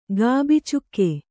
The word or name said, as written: ಗಾಬಿಚುಕ್ಕೆ